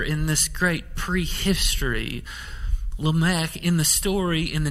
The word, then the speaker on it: Lamech: Lamech, as I was told in East Texas growing up, but maybe Lamech is a better pronunciation.